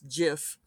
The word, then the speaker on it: GIF: And I said, well, at least it's not an argument like GIF versus JIF, which of course then immediately started the, I cannot believe that there are people out there who want to pronounce it JIF. And Director at Large Monica Valentinelli said, well, did you know that the creator came out and said it's supposed to be pronounced JIF? And I said, yes, but he's wrong. Which she was like, well, what do you mean? I'm like, he's just wrong. It's not. It's graphics interchange format.